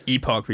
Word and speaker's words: Epoch: Oh, how, do you, how do you pronounce that one? – I would say Epoch.